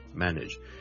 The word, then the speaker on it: manage: Ladies and gentlemen, boys and girls, 今天想跟大家讲 manage 这个字的发音 manage M-A-N-A-G-E manage，由于这个字后面 N-A-G-E nudge 是串做 N-A-G-E，很多人就会将它读成 manage，但是事实上呢，我这个字是读 manage nudge nudge nudge，你当是好像 N-I-D-G-E 那样。 Okay, manage.